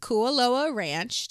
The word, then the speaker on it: Kualoa Ranch: So it was filmed at Kualoa Ranch.